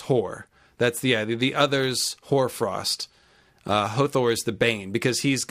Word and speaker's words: Hother: So Emma Smith, Storm Emma, as I like to call her, Archmaester Emma, she points out that Hothor, Horsbane, has some great wordplay. So Hothor is spelled Hother. So it's basically the word other with an H in front.